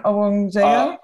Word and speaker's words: Obongjayar: um, with Fred Wave and help me with the pronunciation, Obongjayar? – Uh, Ob- yeah, yeah, yeah, okay. – that's right.